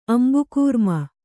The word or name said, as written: ಅಂಬುಕೂರ್ಮ